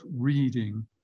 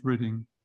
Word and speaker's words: Reading: It's spelt Reading, but it's pronounced Reading.